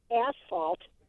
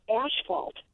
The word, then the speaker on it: asphalt: And I say asphalt, but they say asphalt. And I'm like, well, it's a PH, so it's an F sound, but they say SH.